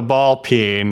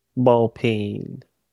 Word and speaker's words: ball peen: And You're looking for the ball peen that's in the aisle right next to the cock. – Ball. – Is that how am I pronouncing it wrong? How do you say it? – Ball peen.